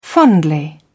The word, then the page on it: fondly: British English